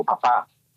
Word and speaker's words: papà: Ma la parola Abba in aramaico è quello che in italiano noi diciamo papà, con l'accento finale, papà, babbo, il mi babbo in Toscana, ecco.